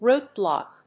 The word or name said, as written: root block